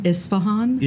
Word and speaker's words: Isfahan: Isfahan.